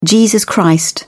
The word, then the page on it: jesus christ: British English